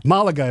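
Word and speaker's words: Málaga: Or Malaga. Malaga, if you say it right.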